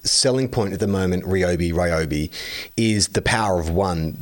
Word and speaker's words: Ryobi: Ryobi is how it's pronounced.